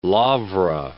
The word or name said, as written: Laura